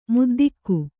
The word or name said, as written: ಮುದ್ದಿಗೆ